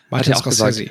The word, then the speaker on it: Martin Scorsese: Martin Scorsese, gesagt, ja.